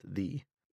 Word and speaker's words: ye: Actually, I learned yesterday that ye in Middle English meant thee, and it was actually pronounced thee.